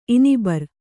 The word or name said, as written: ಇನಿಬರು